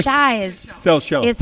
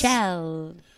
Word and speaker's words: Seychelles: Seychelles. Is how they pronounce it according to the British.